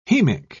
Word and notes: hemic: British English

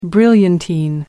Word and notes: brilliantine: American English

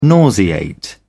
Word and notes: nauseate: British English

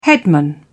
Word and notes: headman: British English

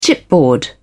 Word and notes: chipboard: British English

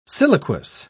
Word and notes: siliquous: British English

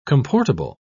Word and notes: comportable: British English

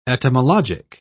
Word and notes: etymologic: British English

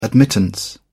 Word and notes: admittance: British English

